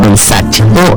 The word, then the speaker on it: Versace: Por ejemplo, Versace, la marca Versace es italiana, entonces tengo que pronunciar Versace.